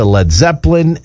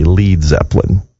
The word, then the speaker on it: Led Zeppelin: Page tweaked it to Led Zeppelin, and then they had to spell it L-E-D so that Americans would not say Lead Zeppelin.